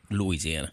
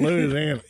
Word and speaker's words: Louisiana: Lord, you didn't even say it right. – Louisiana. – There you go. See, you got to at least get that Louisiana. You got to lose that beginning part, Louisiana.